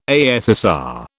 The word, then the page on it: ASSR: British English